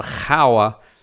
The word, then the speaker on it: Gouwe: The river is spelled G-O-U-W-E. So you'd look at that and go, it's the Gouwe.